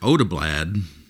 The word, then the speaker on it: Odeblad: Researchers like Eric Odeblad, that's spelled O-D-E-B-L-A-D, Eric Odeblad, he is a Swedish researcher.